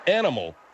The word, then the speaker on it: animal: It's aminal. It's not aminal. It's animal. – Animal, yes. – See what I'm saying? And it's infectious. You can hear Margaret there, you know, butchering it alive, as you would say. – All right. The word is animal. And the way you can remember is if you remember that there's an N before the M.